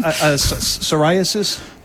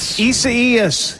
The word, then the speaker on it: Isaias: Isn't that what was that again? – Psoriasis. – Closer. Yeah. Icarus. – Isabel. – Isaias. – Yeah, I know that. I know. Just having a little fun with everybody mispronouncing the storm today on TV and radio.